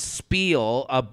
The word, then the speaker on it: spiel: I love Jared's spiel, right? That's how you say that. – Spiel. I think, yeah, is the Yiddish, is the Yiddish pronunciation.